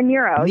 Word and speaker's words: Nyro: Laura Nero. – Nero, yeah. – Is that how you pronounce it? Okay, Nero.